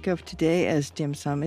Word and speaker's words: dim sum: And I understand that dim sum is a Cantonese pronunciation.